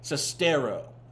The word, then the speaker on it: Sestero: And none of it was illustrated better than when Greg Sesterno, is it Sestero or Sesterno? Hold on. Let me pull up. I don't want to keep saying his name wrong. Sestero. Okay, so let me re-record the opening.